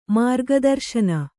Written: ಮಾರ್ಗದರ್ಶನ

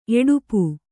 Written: ಎಡುಪು

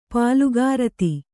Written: ಪಾಲುಗಾರತಿ

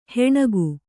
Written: ಹೆಣಗು

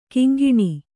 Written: ಘಿಂಘಿಣಿ

